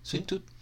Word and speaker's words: Sweet Tooth: Vi el primer capítulo de Sweet Tooth. – ¿Se puede pronunciar así? Sí, pues, Sweet Tooth.